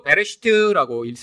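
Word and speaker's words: 베레시트 (בראשית): 자, 창세기는 원래 히브리어로 베레시트라고 읽습니다.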